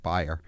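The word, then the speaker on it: Baier: That's B-A-I-E-R. B-A-I-E-R, pronounced Bayer.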